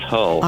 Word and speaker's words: Hull: It is Hull.